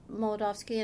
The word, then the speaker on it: Molodovsky: Can you talk to me a little bit before we get started, about Molodovsky, and I hope I'm pronouncing it correctly.